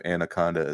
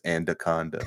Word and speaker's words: anaconda: There's no reason that not being able to roll your R's should lead to the pronunciation of anaconda as andaconda.